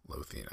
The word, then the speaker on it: Lothian: Lothian, I think is how you say it.